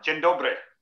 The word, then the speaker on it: Dzień dobry: Dzień dobry. – Ah, that's great. That was a good pronunciation.